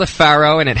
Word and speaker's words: Faro: F-A-R-O. Not as in the ancient pharaohs, but Faro.